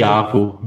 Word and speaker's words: Iago: Äh, und, ähm, wo wir gerade den Vorlagengeber oder den denjenigen äh, erwähnt haben, der das Tor eingeleitet hat, nämlich Iago, heißt wird er ausgesprochen, ne? oder Iago? Ich hab, mir ist das Spiel, Iago, ja.